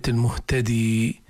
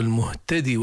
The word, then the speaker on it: المهتد: اللي فيها ياء انطقوها اللي ما فيها ياء لا تنطقوها. في الأعراف مثلا فهو المهتدي ماشي لكن هنا ما فيها ومن يهدي الله فهو المهتد هذا وقفا. طب بدنا نوصلها ومن يهدي الله فهو المهتدي ومن يضلل كذا أرجو انتباه.